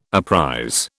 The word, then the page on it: apprize: British English